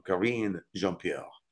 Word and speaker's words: Karine Jean-Pierre: La portavoz de Uncle Joe, Karine Jean-Pierre, se pronuncia, Karine Jean-Pierre, dijo a los periodistas el martes que Biden es el mejor comunicador que tenemos en la Casa Blanca.